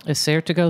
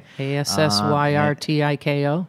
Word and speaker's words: Assyrtiko: And Assyrtiko? – Assyrtiko is perfect. – Okay. – Assyrtiko.